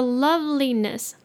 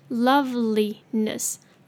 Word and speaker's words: loveliness: 今天是最后一集，所以今天的句子特别长，当作是一个挑战，好不好？好，我们来看，首先 the loveliness, loveliness 可以分音节念 love, 大家应该都会念，没问题。加上后面的 ly 是 li 的音，再来 e 念 a 的音，OK，ness, ness, OK 加在一起 loveliness, loveliness。再来，is drawing,